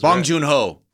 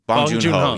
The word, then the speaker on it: Bong Joon-ho: How do you say that dude's name? The dude who wrote Parasite, who directed Parasite? – Oh, I don't know. I thought that was Bong Joon-ho. Bong Joon-ho.